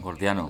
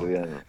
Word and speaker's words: gordiano: Sí, dime, a, hablabas de la disolución de los nudos antes y esto me lleva al nudo jordiano, ¿lo he dicho bien? Jordiano. – Gordiano. – Gordiano, sí, eso. Gordiano.